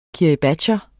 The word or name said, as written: kiribatier